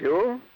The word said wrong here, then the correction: jowl: Jowl, all right. – Jowl? – Jowl. Is that how you pronounce it? – J-O-W-L. Spelled, I think, jowl.